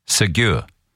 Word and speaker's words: segue: Well, did you know that that word is actually spelled S-E-G-U-E, segue, which I never knew, but it's pronounced segue.